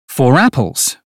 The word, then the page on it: four apples: British English